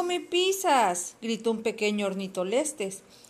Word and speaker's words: Ornitholestes: Se pronuncia Ornitholestes.